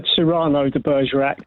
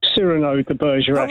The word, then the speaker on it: Cyrano de Bergerac: This is, I would always have said Cyrano de Bergerac, but they pronounced it Cyrano de Bergerac in the play.